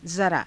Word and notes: zaraa: Hindi

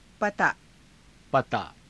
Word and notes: pataa: Hindi

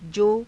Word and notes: jo: Hindi

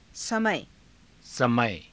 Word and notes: samaya: Hindi

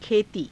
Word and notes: khetii: Hindi

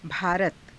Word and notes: bhaarata: hindi

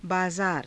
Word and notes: baazaara: Hindi

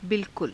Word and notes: bilkula: hindi

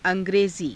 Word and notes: a^grezii: Hindi

